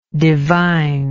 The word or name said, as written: divineness